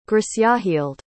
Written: grisjahilde

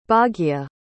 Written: Bhagya

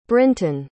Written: brinton

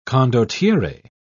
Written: condottiere

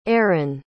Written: arron

